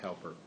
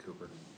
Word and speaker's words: Cowper: Not pronounced Cowper, pronounced Cooper.